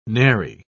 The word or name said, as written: nary